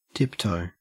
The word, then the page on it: tiptoe: Australian English